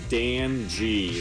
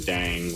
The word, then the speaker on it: Dan.G: This is Dan.G. They spell it Dan.G. He told me that sometimes people call him Dang, which he's like, we're into that. But yeah, Dan.G.